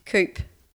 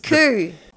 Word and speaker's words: coup: This is a tricky one, actually, because it's got a silent letter in it. The P silent. – Cup. – No. This. – Coo. Coo. – Yes. Coo. Well done.